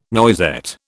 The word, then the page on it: noisette: British English